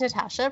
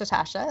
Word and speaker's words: Natasja: Hi, it's Natasha, pronounced Natasha.